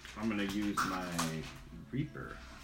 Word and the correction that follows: rapier: Your rapier? – Rapier. – That's how it's... That's how you say it.